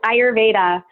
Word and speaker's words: Ayurveda: Yes, Ayurveda.